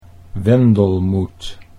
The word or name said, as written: Wendelmoet